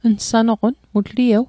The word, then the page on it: nsanoqon: Mi'gmaq